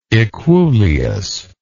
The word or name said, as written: equuleus